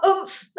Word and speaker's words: Oomph: Am I saying that correctly? – Yeah, you are. Oomph. That's right. Give it some oomph. That's exactly right.